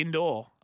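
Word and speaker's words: Indore: Indoor. – I thought you were maybe... – No, I wasn't throwing it up. Yeah, but I could have pronounced it incorrect, but it's I-N-D-O-R-E, indoor.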